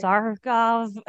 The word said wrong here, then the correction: Svarog: I'm not saying this right, because... – Without Kate here, we are not on top of our historian. It's Project Svarog. – I said it wrong, but I knew what it was.